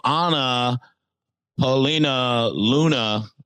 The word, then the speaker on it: Anna Paulina Luna: Also, Anna Paulina Luna. Am I saying that right? – You are.